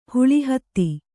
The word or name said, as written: ಹುಳಿಹತ್ತಿ